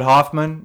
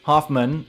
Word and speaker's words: Hoffman: All right, for David Hoffman, you guys can find me on Instagram at @DavidHoffman_actor. Hoffman spelled with double the F, double the N.